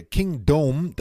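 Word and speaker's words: Kingdome: Das alte Stadion der Seahawks wurde meines Wissens nach King Dome ausgesprochen wie der Dome und nicht Kingdom wie das Königreich.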